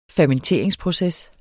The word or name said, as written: fermenteringsproces